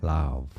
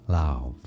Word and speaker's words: Lauv: Lauv is how to pronounce it. Lauv.